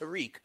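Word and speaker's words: Tarik: I hear his first name is pronounced Tariq.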